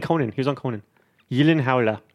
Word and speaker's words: Gyllenhaal: And his last name is actually pronounced Gyllenhaal. – Gyllenhaal. Yeah. – Again, can you confirm this? – Gyllenhaal.